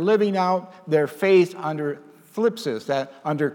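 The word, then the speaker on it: phlipsis (θλῖψις): Now, the Greek word here is for tribulation is the word phlipsis. I said that correctly, phlipsis.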